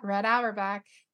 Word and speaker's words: Auerbach: Red Auerbach. – Yeah. Red Auerbach.